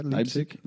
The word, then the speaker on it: Leipzig: Leipzig, is that how you pronounce it? Leipzig? – Yeah, Leipzig.